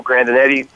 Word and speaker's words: Grandinetti: Everything is under my name, which is Michael Grandinetti. My website's under Michael Grandinetti.